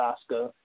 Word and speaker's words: Asuka: Team Raw, Alicia Fox, the captain, Nia Jax, Asuka, you know, also known as Asuka, but they pronounce it Asuka, so we'll go with Asuka.